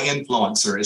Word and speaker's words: INFLCR: It's actually pronounced, they go by influencer.